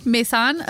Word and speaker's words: Maysan: Oh, Paul's listening to this going, that is not how you pronounce that. – No, it's not how you pronounce it. I'm so sorry, Paul. – Probably Maysan. – Maysan? Okay. Yeah. M-A-Y-S-A-N.